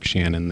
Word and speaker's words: Schanen: I think I am the other Mark Shannon. There's two of us. My last name is spelled S-C-H-A-N-E-N.